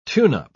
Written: tune-up

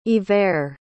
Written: Iver

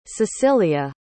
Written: secilia

